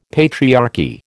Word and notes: patriarchy: American English

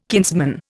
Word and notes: kinsman: Indian

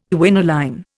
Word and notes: quinoline: Indian

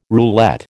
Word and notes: roulette: American English

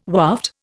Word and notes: waft: Indian

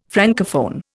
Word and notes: Francophone: Indian

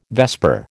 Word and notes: vesper: American English

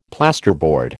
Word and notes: plasterboard: American English

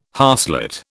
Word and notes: harslet: British English

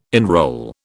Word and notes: enrol: British English

